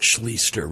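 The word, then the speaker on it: Schlichter: And his name, by the way, is S-C-H-L-I-C-H-T-E-R. I remember that from Art Schliester. Remember Art Schliester? Wasn't he like the Ohio State quarterback or something? But that's how he pronounced his name. So I'm assuming that this gentleman pronounces it the same.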